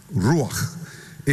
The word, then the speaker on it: ruach: Ruach.